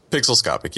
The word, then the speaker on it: Pixelscopic: Pixelscopic, yeah, you got it right.